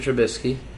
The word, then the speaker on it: Trubisky: All right, Mitch Trubisky. I don't even know if I'm saying his name right. – Yeah, Mitchell Trubisky.